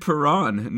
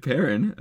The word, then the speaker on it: Perron: It is David Perron, not David Perrin, apparently. – Perron, the more French-inflicted pronunciation.